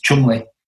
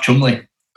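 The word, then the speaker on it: Cholmondeley: But the English way to say it or to pronounce it is Chumley. – Chumley. – Chamondele is how you spell it. – Yeah. – And then Chumley is how you pronounce it.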